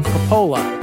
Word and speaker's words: Coppola: Thanks to Vinny Coppola. Coppola? How do you say it? – Coppola. – Coppola. Coppola. There we go. – Yeah, like cola with cola. Coppola.